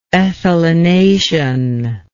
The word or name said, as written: ethylenation